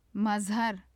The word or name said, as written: mazhar